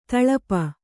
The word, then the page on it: ತೊಳಪು: toḷapu